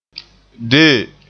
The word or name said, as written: dee